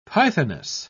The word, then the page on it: pythoness: British English